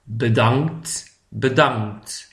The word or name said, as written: bedankt